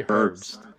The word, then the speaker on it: Herbst: Did I say it right? – Who? – Did I mess it up again? – Riley Herbst. – Herbst. My bad. I don't know why I said his last name like that. Leave me alone, okay? Anyways. – He literally pronounced every single letter.